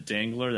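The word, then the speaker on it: Dengler: Yeah, you can just follow me on Twitter at Sean Dangler. That's D-E-N-G-L-E-R. The way I say my name, many people think it's D-A-N-G-L-E-R. D-E-N-G-L-E-R.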